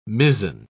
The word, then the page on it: mizen: British English